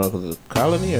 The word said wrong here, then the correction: Conley: or Colony, whatever dude's name is. From, Memphis. – Oh, Mike Conley. – Yeah, Conley.